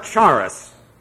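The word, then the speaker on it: Charis (χάρις): Then you have the alpha, then the rho, and then the iota, and then the final S. Charis. It's not charis. It's terrible.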